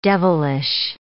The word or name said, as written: devilish